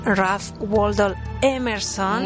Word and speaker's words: Ralph Waldo Emerson: Pues también habría que mencionar a Ralph, a ver si lo digo bien, Ralph Waldo Emerson.